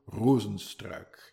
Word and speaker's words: Rozenstruik: Uh, in which I break down his fight against Jairzinho Rozenstruik, and that is the way you pronounce it, Jairzinho, Jairzinho Rozenstruik. He's Dutch.